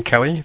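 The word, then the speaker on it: Kelley: My username there is Brian Kelly. That's spelled B-R-I-A-N-K-E-L-L-E-Y.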